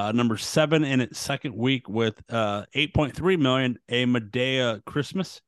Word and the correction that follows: Madea: Medea, say it right. – Medea. – Medea. – Medea, no. Number Madea 6.